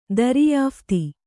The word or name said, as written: ದರಿಯಾಫ್ತಿ